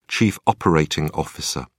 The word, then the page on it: chief operating officer: British English